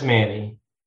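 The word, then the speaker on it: Many: Is it pronounced Manny or is it some weird Cajun accent that I don't know? – It's just Manny.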